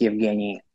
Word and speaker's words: Evgueni: Yeah, the U is silent. It's just Evgueni.